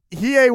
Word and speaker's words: Hiei: Hiden. – Hiei won, yes. You can't say his name properly, but Hiei did win.